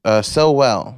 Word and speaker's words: Sowell: Sowell. Like you know him so well.